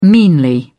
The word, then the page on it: meanly: British English